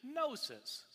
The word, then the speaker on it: gnosis: The Greek word for knowledge is gnosis, spelled with a G, G-N-O-S-I-S.